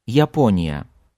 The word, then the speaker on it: Япония: Just listen how I pronounce Japan in Russian. Япония. Япония. Япония. This word begins and ends with Я, but you only hear well-defined Я at the beginning. At the end, it sounds less powerful and less pronounced.